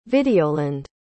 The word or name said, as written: videoland